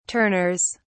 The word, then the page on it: turners: British English